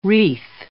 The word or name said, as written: wreath